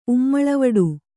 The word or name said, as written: ಉಮ್ಮಳವಡು